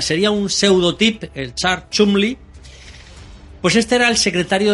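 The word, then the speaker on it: Cholmondeley: Cholmon de Ley, pero la pronunciación era Chumley.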